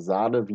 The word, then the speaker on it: Sané: Sané hat ja auch schon Samstag gegen, oder Sahne, wie er ja ausgesprochen wird, äh, Sidi Sahne, äh, äh hat Samstag schon gegen RWE im Testspiel gespielt und ist da schon eingesetzt worden.